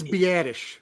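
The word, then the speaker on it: Biadasz: Biderez is, I think you pronounce it Biderez. – I think it's Biadish. – Biadish, yes, you're right.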